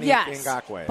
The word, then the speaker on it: Ngakoue: So, like, Yannick Ngakoue. – Yes, Yannick Ngakoue. – Theirs is actually, that's an audio link. Mm. So you click on it, and you hear how it's pronounced. – A computer voice tells you. – Yes. – AI tells you, Yannick Ngakoue.